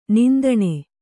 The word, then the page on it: ನೊಂದಣಿ: kannada